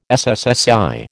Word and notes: SSSI: American English

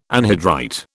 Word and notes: anhydrite: British English

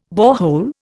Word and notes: borehole: Indian

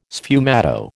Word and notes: sfumato: American English

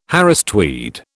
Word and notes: Harris tweed: British English

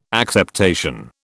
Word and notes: acceptation: British English

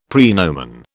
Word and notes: praenomen: British English

